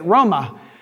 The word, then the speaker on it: Roma: Roma, Italy. That's how they say it. Roma.